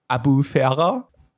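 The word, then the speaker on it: Albufeira: die hieß Albufera, ausgesprochen Abufera. Das L ist stumm.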